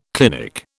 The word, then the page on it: clinic: British English